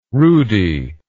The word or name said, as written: rudy